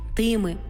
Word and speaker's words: тими: Порівняйте. Тими, а не тими. Цими, сими, але всіма, усіма.